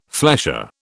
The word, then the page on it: flesher: British English